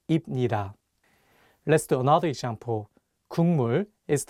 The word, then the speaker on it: 국물: The consonant 기역 is for G sound in English, so you can read it as 국, but it's paired with the nasal sound 미음, so it changes the pronunciation from a G sound to an NG sound, and you'll need to read it as 국물.